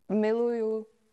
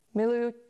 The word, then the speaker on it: miluju: Miluju. Yeah, or vás as if you like more people. – Miljukets. – Miluju, yeah.